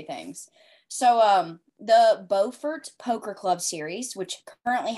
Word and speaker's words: Beaufort: So the series that I'm currently writing through is the Beaufort Poker Club, based in Beaufort, North Carolina. And the funny thing about it is there's also a Beaufort, South Carolina, spelled the exact same way, but they pronounce it as Beaufort.